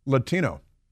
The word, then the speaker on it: Latino: You know, there's an English pronunciation of Latino. It goes like this, Latino. See how that works? L-A-T-I-N-O. For Joe Biden, of course, it's Latinx.